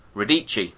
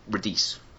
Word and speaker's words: Radici: And I did get the name of one of the actors wrong. Giovanni Lombardo Radici. And I said Radice, because I read it phonetically, because I'm a moron.